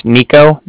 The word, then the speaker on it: Niko: Okay, so like I said, my name's Nico. That's spelled N-I-K-O. And then the Bank of Cardiff is spelled C-A-R-D-I-F-F, as in Frank Frank.